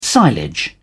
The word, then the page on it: silage: British English